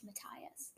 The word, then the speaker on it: Matthias: I think it's officially pronounced Matthias. – But it sounds like Matthias. I like Matthias better. – I've always said it Matthias.